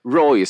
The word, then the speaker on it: Roy: Patrick Roy, som man sa någon liten, så kom man på att det Roy, för man ska uttala det franskt.